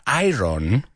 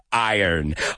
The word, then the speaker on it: Iron: There it is, Fear of the Dark by Iron Maiden, que ya sabemos que no se pronuncia Iron, sino Iron.